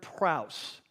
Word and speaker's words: praus: So the word in Greek for that's translated meek is the word praus, and it's spelled like this, P-R-A-U-S, okay, praus, P-R-A-U-S.